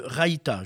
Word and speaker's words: raita: c'est le raita.